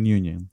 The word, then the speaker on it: Nguyen: Es Nguyen, Nguyen, Yo te decía Nguyen, porque así decía yo. Se pronuncia Nguyen.